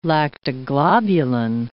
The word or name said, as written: lactoglobulin